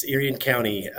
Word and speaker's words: Erie County: Erie County? – Yes, Erie County.